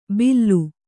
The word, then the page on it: ಬಿಲ್ಲು: billu